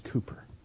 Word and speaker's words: Cowper: It's spelled Cowper, C-O-W-P-E-R, but it's pronounced Cooper.